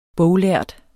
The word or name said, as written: boglærd